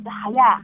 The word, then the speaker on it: Haya: Haya, it's a Mongolian language. Pronunciation is Haya.